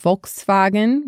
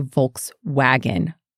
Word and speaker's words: Volkswagen: For their V, which sometimes sounds like an F, a F, F, F sound, we would pronounce that like an American V. So instead of Volkswagen, which sounds a little bit like an F to me, we would say Volkswagen.